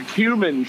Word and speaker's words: cumin: Yeah, like an old cum shirt? – Yeah, it's like an old sock. – No, it's a cumin shirt. – Oh, cumin shirt. Sorry, I mispronounced it again.